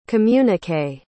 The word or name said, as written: communiqué